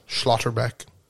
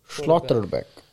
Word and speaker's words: Schlotterbeck: Schlotterbeck. – Schlotterbeck.